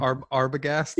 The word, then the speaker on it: Arbogast: He's good as Arbogast or Arbogast. How do you say his name? – Arbogast.